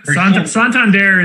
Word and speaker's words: Santander: They actually talked about how to pronounce that on MLB Network this morning. I was just catching up on some of last night's games. And they were saying Santander, and I think they settled on Santander. – Like, yes. Santander is the way that I guess you're supposed to say it.